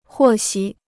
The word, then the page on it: 获悉: huò xī